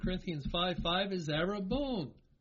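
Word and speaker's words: Arabone (ἀρραβών): The Greek word, how is it pronounced? Arabone. Arabone. It is pronounced exactly as the Hebrew word that we were just looking at in Genesis 38, translated as pledge.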